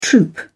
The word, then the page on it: troop: British English